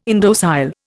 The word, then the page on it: indocile: Indian